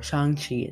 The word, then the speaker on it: Shang-Chi: And then we had Shang-Chi, or Shang-Chi is the proper pronunciation of it, that came out in 2021.